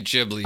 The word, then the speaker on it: Ghibli: a Studio Ghibli movie. – No, you're actually like, saying it right. – If Heaven exists... – It is supposed to be Ghibli.